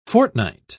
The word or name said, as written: fortnight